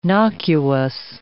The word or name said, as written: nocuous